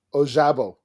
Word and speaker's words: Ojabo: Ojabo? – Ojabo, yeah.